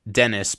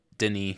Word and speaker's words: Denis: His first name is Denis. I know that. You know, it's Dennis, but Denis, because he's European, somewhere from Europe.